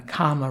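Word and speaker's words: kāma: But of course, the sexual desire, the romantic desire, is kama raga. This is sexual desire, and it's a part of desire. This is called we can say kama chanda, desire. Kama, k long a m a, is desire that's connected with the five senses.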